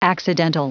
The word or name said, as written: accidental